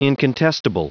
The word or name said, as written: incontestable